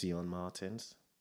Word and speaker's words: Sion Martins: Sion Martins, am I saying that right? – Yeah, that's my name. Sion Martins. There's a T in it, but it's silent, so you got it.